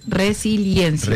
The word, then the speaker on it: resiliencia: Y la pronuncian mal, la dicen mal, es resiliencia.